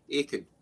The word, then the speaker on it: Aitken: It's Jim Aitken, A-I-T-K-E-N. How do you pronounce your last name? Is it Aitken or what? – The silent T, Aitken.